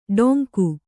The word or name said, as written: ಡೋಂಗು